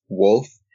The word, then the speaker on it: WXLF: His name is Wolf, but it's spelled WXLF.